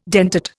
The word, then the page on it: dentate: Indian